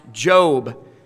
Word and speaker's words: Job: And, of course, we know that the name is pronounced Job, but it is spelled as what? Job.